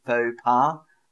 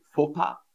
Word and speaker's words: faux pas: So that's how an English person would say it. We say faux pas. But of course, if I want to sound more French, I would say faux pas.